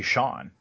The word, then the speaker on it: Chone: All right, so next up is a player whose name I'm never totally sure how to pronounce the first name, because it looks like Chone Figgins, but I've also pronounced basically like Sean Figgins. – I think it's supposed to be Sean.